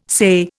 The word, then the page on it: say: Indian